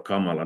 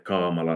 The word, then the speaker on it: kamala: Men att det finska ordet så uttalas att snabbt med tre kort huggna stavar, alltså Kamala, det är inte Kamala eller Kamala.